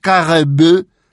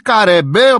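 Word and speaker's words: Karembeu: Να έχουμε μια γλωσσική ακολουθία και όχι όπως ο ανεκδίκητος ο σπορκάστερ που άκουγα έλεγε τον ποδοσφαιριστή του Ολυμπιακού Καρεμπέ, με μισή από τη μέση και κάτω γαλλική προφορά και μισή ελληνική. Ή θα τον πεις Καρεμπέ ή Καρεμπέ όπως λέμε Καραμπουζουκλής. Κλείνω την παρένθεση.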